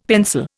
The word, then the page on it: pencil: Indian